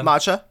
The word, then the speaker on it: matcha: I got my milk tea one and it's pronounced matcha, right? That's the one? That's the matcha.